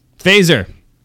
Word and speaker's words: Faesser: Survey says Andy Fazer. I think that's how you pronounce his last name. – Is notoriously good at pronouncing people's names. – Yes. F-A-E-S-S-E-R. – Fazer.